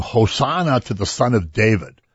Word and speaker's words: Hosanna: And if you say Hosanna, it means come and save us now. It's like emphasis on when you accent the last syllable.